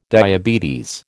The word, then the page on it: diabetes: American English